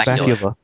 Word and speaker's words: Bakula: I said Scott Balakka. I can't pronounce Bakula.